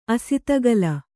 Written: ಅಸಿತಗಲ

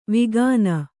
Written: ವಿಗಾನ

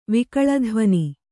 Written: ವಿಕಳಧ್ವನಿ

